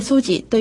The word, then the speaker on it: SOGI: はい、 SOGI と書いて、ソジというふうに読むんですが、まず SO というのがセクシュアルオリエンテーションという言葉になるんですけれども、これを日本語訳すると性的指向。